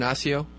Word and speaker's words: Osseo: Is that the right pronunciation, Osseo? – Yes, that's okay.